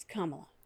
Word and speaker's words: Kamala: I also, we are recording this in the same week that Joe Biden announced Kamala Harris as his running mate. And for the record, because everyone in the left media is pronouncing it incorrectly, it's Kamala. Kamala. Kamala. We all got that? – Kamala.